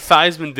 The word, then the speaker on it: Theismann: Yeah, but Theisman did it because his name would rhyme with Heisman.